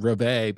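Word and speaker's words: reveille: But it turned out revelry is actually pronounced revey, but I don't think anybody in America pronounces it that way. – Yeah. I've always pronounced it revelry.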